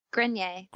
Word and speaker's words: Grenier: Is it Grenier? – Grenier. – Okay. – The R is silent.